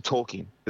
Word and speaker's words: Tolkien: It's driving you to Tolkien.